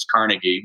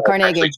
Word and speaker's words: Carnegie: One of the things I learned is that's how you say his name. I always thought it was Carnegie when they say Carnegie. Oh, no, Carnegie.